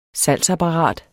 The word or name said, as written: salgsapparat